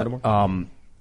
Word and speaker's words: Holodomor: The Holodomor, is that how you pronounce it? – Yeah. Holodomor.